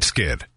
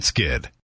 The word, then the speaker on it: Skydd: Plant Skid. That's spelled plant, SKYDD. Plant Skid.